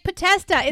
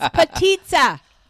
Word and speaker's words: Potica: you're trying to make it say like patesta. It's not, it's patita. – That's what I said. – No, you don't. Try it again. – Anyway. – Try it again. – Patista. – No. Patita. – Spell it. – P-O-T-I-C-A.